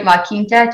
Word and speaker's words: La Quinta: Yeah, he sold another one in, am I going to say this right? La Quinta, did I say that right? – Yep, you said it right.